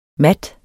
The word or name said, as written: mat